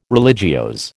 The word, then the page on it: religiose: American English